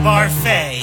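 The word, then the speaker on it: Barfée: tragic setback, William Barfy. – It's pronounced Barfay. There's an accent ado.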